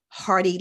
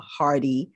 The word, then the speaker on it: Hardee: People should find me on Instagram at Hardy Life. My last name is spelled H-A-R-D-E-E Life, right? H-A-R-D as in delightful, two E's as in exciting. So... And you can find me on Clubhouse under Natasha Hardy.